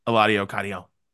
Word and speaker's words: Eladio Carrión: Eladio Carrión.